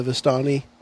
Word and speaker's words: Vistani: So then we would have gathered the information we did from the Vistani.